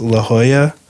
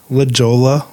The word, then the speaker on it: La Jolla: So just to correct ourselves, it's La Jolla, not La Jolla or La Joya.